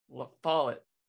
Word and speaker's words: La Follette: It's La Follette. Rhymes with wallet. – La Follette.